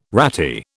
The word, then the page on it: ratty: British English